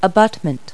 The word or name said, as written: abutment